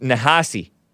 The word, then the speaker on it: Ta-Nehisi Coates: Ta, and it looks like Nahisi, but it's pronounced Nahasi. Coats. Ta, T-A, hyphen, capital N-E-H-I-S-I, something like that. And then last name, Coates, C-O-A-T-E-S.